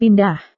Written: pindah

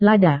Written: lada

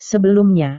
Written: sebelumnya

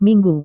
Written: minggu

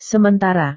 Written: sementara

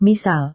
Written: misal

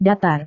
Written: datar

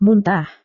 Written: muntah

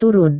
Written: turun

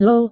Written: lho

